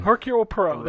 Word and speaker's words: Hercule Poirot: Agatha Christie, is it Hercule Poirot? – The London Hercule Poirot. – Hercule Poirot, there you go.